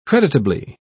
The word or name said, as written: creditably